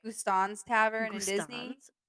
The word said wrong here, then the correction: Gaston's: Is that, did I say that right? – Guston's. Gaston's. – Gaston's, sorry.